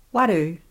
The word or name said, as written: waru